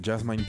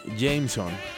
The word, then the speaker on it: JMSN: No sé cómo pronunciarlo, es JMSN y no le hallo como un, acrónimo, pensé que era como Jasmine, pero no.